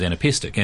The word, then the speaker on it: anapestic: I was reading about it this morning, anapestic. – That's the one. Anapestic. Anapests are a kind of, a rhyme form where there is three syllables and the third syllable is the heavy syllable, the accented syllable.